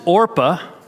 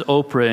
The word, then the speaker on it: Orpah: The name of the one is Orpah. You'll probably mispronounce that as Oprah in your small groups, just because Oprah is so famous.